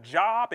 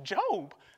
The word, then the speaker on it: Job: I've been looking at this, this, this book, the book of Job, and I don't understand it at all. Like, to God be the glory. He doesn't even know it's pronounced Job.